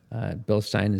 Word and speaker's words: Bilstein: How do you pronounce it? Bilstein or I say Bilstein.